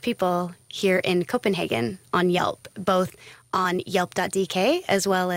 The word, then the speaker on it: Yelp: And we're pretty excited because when people ask about Yelp, I always say it rhymes with help.